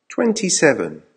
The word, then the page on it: twenty seven: American English